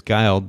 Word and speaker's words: Guild: I mispronounced your name, Guild, earlier. It's Guild, but Tavis and Amy Guild.